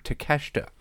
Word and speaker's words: Takeshita: That's how you say his name, Takeshita.